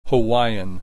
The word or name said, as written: Hawaiian